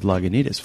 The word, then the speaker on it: Lagunitas: One of them is you just pronounced Lagunitas right. Really, think about that for a moment, you know, because when we first began, you know, it's like I lived in the town of Lagunitas, and I knew that most people in the towns around us didn't pronounce Lagunitas. It was Lagunitas and Lagunitas and all sorts of things.